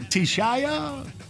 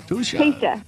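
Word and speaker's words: Teshia: Is it Tishaya? – Tisha. – Tisha. All right.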